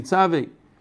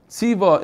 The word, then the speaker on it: tzavei: And because of that, b'schar zois, in that schar, keil chai chalkenu, tzavei, or tziva, there's a machlokes here, how to say it exactly, we say tzavei, or tziva in the past, lahatzil yididus she'irenu mishachas.